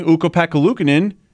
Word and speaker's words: Ukko-Pekka Luukkonen: And then speaking of embarrassing, you didn't even mention the fact that PK Subban keeps calling Ukko-Pekka Luukkonen.